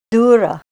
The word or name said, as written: dura